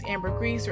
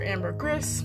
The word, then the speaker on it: ambergris: Now, people may pronounce it several different ways, ambergris or ambergris, but it is a traditional fixture in a lot of expensive perfumes.